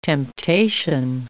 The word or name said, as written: temptation